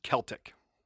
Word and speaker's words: Celtic: I hate when people mispronounce the word Celtic. – Okay. – And this is very common in America here because we actually have a basketball team called the Boston Celtics, and people will say the Boston Celtics. Like, every single fan of the team will call them the Celtics. – I've only ever heard them called the Celtics. – Well, you've only ever heard them called the wrong thing because it's Celtic with a hard C.